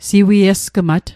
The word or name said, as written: siwiesgmat